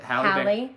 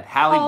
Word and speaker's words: Halle: Halle Berry. How do you say it? – Halle. – My bad. Halle Berry.